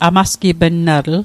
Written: amasgipnnatl

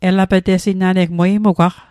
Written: elapetesing

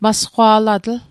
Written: masqwa'latl

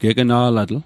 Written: gegna'latl

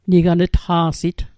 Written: nigan'tqa'sit

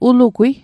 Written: ulugwi

